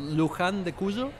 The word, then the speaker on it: Luján de Cuyo: But you're in the, let's see if I can say this correctly, Luján de Cuyo? – Yes. – How should I say it? – Yes, exactly. It's called Luján de Cuyo.